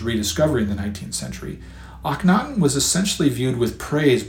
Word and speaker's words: Akhenaten: It weighed so heavily on his mind that, in the fifth year of his reign, he changed his royal name to Akhenaten, and that is spelled A-K-H-E-N-A-T-E-N.